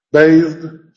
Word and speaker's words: bathed: Así está escrito, pero está pronunciado como una sola sílaba. Bathed. Bathed es una sola sílaba.